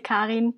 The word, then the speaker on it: Karin: I mean, I'm from Germany, so usually I say Karin, but I got used to the English way, so Karen is perfectly fine.